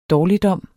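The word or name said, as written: DÅRLIGDOM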